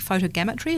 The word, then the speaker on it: photogrammetry: The colours are amazing, and when you have an understanding of how it's been made using, I think, photogrammetry, is that how you pronounce it? – Yep, yes.